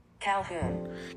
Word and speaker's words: Calhoun: Lena Mary Calhoun, how do you say it? – Calhoun.